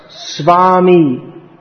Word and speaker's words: Swami: That's usually pronounced as Swami, written in English S-W-A-M-I. And as far as I know, there are no... Everyone says like that. What's written as V is pronounced more like a W.